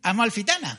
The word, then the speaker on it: Amalfitana: Amalfitana.